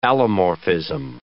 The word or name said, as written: allomorphism